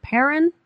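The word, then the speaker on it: Perron: How do you say their last name? Is it Perrin? – Yeah. – Yeah, just Perrin? Mm-hmm.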